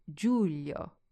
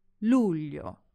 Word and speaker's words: luglio: Watch out for this one because learners often get mixed up and say Giulio with a J. I did this for a while and it feels like a phase that we all have to go through. But just remember that in Italian it's actually L. Luglio.